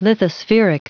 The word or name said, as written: lithospheric